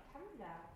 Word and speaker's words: Tumblr: Wie kann man das aussprechen? Mit B-L-R hinten geschrieben. Tumblr. Unmöglich auszusprechen. Kann es jemand? Weiß es jemand, wie es rauskommt? Kennt es jemand? Was? – Tumblr. – Tumblr? Warum schreibt man das nicht?